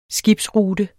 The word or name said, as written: skibsrute